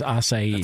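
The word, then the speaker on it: açaí: Speaking of Brazil, I didn't realize that the proper pronunciation... was acai and not acai. I thought it was like acai bowl, and somebody said, no, it's acai.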